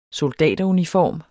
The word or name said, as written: soldateruniform